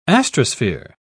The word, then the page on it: astrosphere: British English